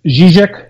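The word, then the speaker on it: Žižek: You're the managing editor and co-founder of the International Journal of Zizek. Am I saying that correctly? – It's Zizek. It's a sort of soft Z. Okay. It's got the little carrot above it.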